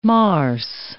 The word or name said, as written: marse